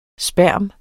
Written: sperm

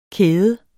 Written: kæde

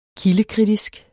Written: kildekritisk

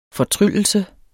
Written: FORTRYLLER

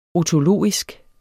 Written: otologisk

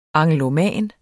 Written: angloman

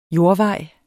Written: jordvej

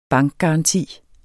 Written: BANKGARANTI

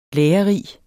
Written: lærerig

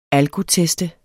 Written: alkotest

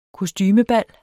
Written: kostumebal

